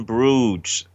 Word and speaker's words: Bruges: No, In Bruges. – So In Bruges. Okay. – Bruges is spelled B-R-U-G...